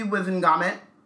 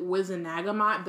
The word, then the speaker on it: Wizengamot: So, we took this to the Wizengamot. – I think in the movies they pronounce it Wizenagamot, but then I'm like, there's no extra A in there, so where'd you get that from?